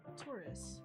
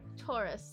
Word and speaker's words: Taurus: Is that how you pronounce it, Taurus? – Taurus. – My bad. Taurus.